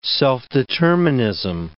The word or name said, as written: self-determinism